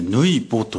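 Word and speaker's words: 抜穂: これはね、ぬいぼと読む。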